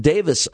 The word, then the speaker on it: Davies: Ray Davies, they pronounce it Davis overseas, by the way. That E is silent, but we pronounce it Davies over here.